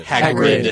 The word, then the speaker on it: Hagrid: Hagrid. – Yeah. – Yes. – Hagrid shows up and he's like. – Hagrid or Hagrid? – Hagrid.